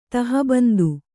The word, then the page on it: ತಹಬಂದು: taha bandu